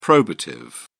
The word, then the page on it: probative: British English